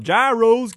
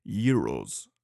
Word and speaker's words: gyros: They have this thing, like the Americans would call it, gyros, give me one of them gyros. It's actually pronounced euros, okay?